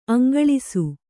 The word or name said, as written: ಅಂಗಳಿಸು